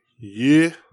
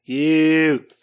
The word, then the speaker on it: Yuu: With her teammate, Yeah. Y-U-U. – Okay. – And she's actually one of... – I believe that's pronounced Yeah. – Yeah.